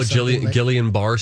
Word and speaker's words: Guillain-Barré: He had caught, is it called like, I'm going to say it wrong, but it's like Guillain-Barré syndrome. – Oh, Guillain-Barré syndrome.